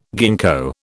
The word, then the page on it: gingko: British English